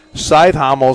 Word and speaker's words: Cajthaml: C-A-J-T-H-A-M-L. Scythhommel is how they pronounce it.